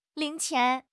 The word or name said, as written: ling2 qian2